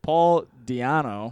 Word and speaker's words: Di'Anno: In the early 1980s, Iron Maiden replaced coke-addicted Paul Di'Anno.